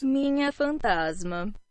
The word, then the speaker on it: Minha Fantasma: Minha Fantasma.